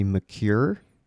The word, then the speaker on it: Maker: What is the correct pronunciation of his name? I think everybody says Maker, though. – I think he has said it's technically McCure, but everyone does say Maker.